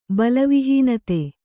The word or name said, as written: ಬಲವಿಹೀನತೆ